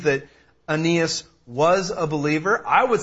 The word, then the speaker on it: Aeneas: And yes, that's how you pronounce that. Aeneas. It doesn't look like Aeneas, but it is Aeneas.